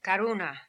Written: Karoona

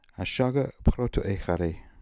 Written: Ashagiprotoehary

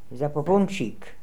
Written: Zapoopoonchik

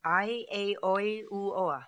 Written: Aieioiuoa

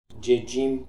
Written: Djedjim

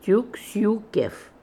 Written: Took soo kef

